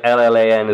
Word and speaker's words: Llan: I know, like, LLAN is like clan.